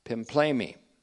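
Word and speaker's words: pimplemi (πίμπλημι): That word pimplemi is used. Again, we've seen that. P-I-M-P-L, long E-M-I, pimplemi.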